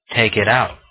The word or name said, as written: take it out